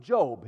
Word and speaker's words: Job: No, it's Job. His name is Job. His name's not Job. It's spelled the same, but pronounced differently.